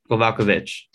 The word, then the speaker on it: Kowalkiewicz: Carolina Kowalkiewicz. – Kowalkiewicz.